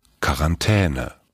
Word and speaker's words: Quarantäne: So wird's ausgesprochen. Quarantäne. – Perfekt. – Ja, das ist offiziell von der Duden-Seite.